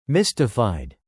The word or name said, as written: mystified